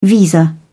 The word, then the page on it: visa: British English